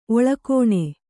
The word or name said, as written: ಒಳಗಾಣ್